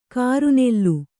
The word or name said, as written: ಕರ್ನಲ್ಲು